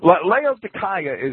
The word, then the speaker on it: Laodicea: Laodicea is literally and and I, uh, you know the Greeks and the Romans always pronounced the K hard, and it's a K.